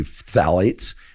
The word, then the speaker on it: phthalates: And she refers to phthalates, which is spelled P. So it's both a plosive and a th. So it's combining the two.